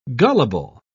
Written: gullible

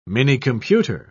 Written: minicomputer

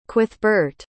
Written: cuithbeart

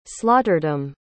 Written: slaughterdom

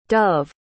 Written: dove

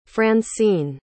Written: francene

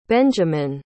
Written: benjamen

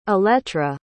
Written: elettra

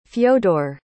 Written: fyodor

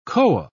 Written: koa

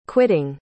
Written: quitting

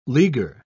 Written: leaguer

